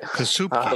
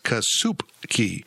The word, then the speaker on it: Kasupke: Plus, just add in the fact Luke Kazubke. I think I got that right. – Kasupke. – Looks good to me. – Actually, I went to the phonetic pronunciation of the media guide. It's Kasupke.